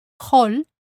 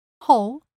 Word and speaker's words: hall: And there are indeed a few words in Spanish with a non-silent H. But they are not really an exception, since the Spanish pronunciation rules do not apply to foreign words, and those words tend to come from English. For example, hall, hall, hobby, hobby. But remember that that's just a handful of words.